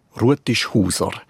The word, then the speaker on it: Rutishauser: Bevor wir die Herkunft von dem Namen klären, wie spricht man ihn just aus? Rutisch Huser. Das ist einer von denen Namen, wo man auch heute noch ganz traditionell mundartlich ausspricht.